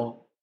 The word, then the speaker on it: om: If you'd like, you can join me for om. It's four syllables. Ah, oo, om. The fourth syllable is silence.